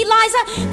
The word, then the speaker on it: Liza: Liza with a Z, not Lisa with an S, cause Lisa with an S goes snods. It's Z instead of S, lie instead of Lee, it's simple as can be.